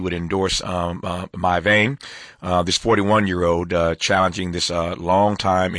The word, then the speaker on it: Mai Vang: Her name again is My Vein. That's spelled M-A-I-V-A-N-G, My Vein.